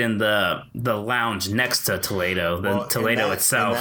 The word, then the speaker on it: Toledo: Toledo, yes. Not Toledo, Ohio. – Yeah